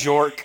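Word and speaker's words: Björk: Bjork, the J is silent.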